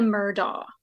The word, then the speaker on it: Murdaugh: But I really think it's the Murdaugh. I mean, I have heavily researched it, and I'm pretty sure it's a Murdaugh.